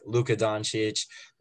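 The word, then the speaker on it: Luka Dončić: My first pick, Luka Doncic.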